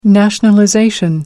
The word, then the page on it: nationalization: American English